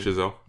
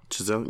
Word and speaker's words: Chazelle: I'm pronouncing that right? Damien Chazelle. – Chazelle, yeah.